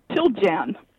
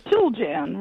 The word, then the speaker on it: Chiljan: Chiljan. – Chiljan. Chiljan. Yeah. All right. Chiljan. – Chiljan, right. Chilly in January.